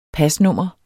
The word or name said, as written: pasnummer